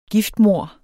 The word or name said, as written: giftmord